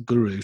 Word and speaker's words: Guro: That's spelled GURO tank. So it's a Guru tank, and a 200-watt mod, dual battery.